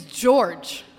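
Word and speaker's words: Georges: But I don't think you could tell, because when Saltine Boy said that, my only retort was, my uncle's name is George. And yeah, it has a silent S at the end because it's French, just like my last name.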